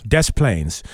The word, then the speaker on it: Des Plaines: Yes, it was sold in Illinois, purchased at a gas station in Des Plaines. I believe that's how they pronounce that. – They actually pronounce the S. – Des Plaines.